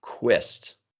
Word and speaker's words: QSST: And some people pronounce the acronym QUIST, which is fantastic. Have an acronym and then call it QUIST.